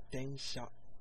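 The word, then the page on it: densha: Japanese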